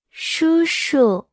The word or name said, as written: 叔叔